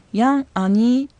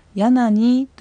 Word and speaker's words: ヤンアニ: このヤンアニというのは普通に話す速度で発音した場合はヤナニという形になります。ゆっくり発音する際にはヤンアニと発音しても構いません。